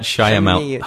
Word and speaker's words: Shyamalan: Shamala Shamyala. – M. Night Shyamalan. Fuck. M. Night Shyamalan. M. Night Shyamalan. Anyway, he said to Mel famously, M. Night Shyamalan.